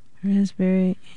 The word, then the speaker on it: raspberry: K-N-E-E-L, Neil. – Raspberry, answer. – Silent right. letters. – Yes, silent letters. Oh, yeah. Raspberry is... – the silent S... – P. – Or a P. Sorry. – Silent P, right?